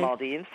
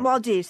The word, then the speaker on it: Maldives: Maldives? How do you say that? – I don't know. – Well, anyway. – Maldives? – Yeah. – Maldives.